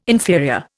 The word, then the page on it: inferior: Indian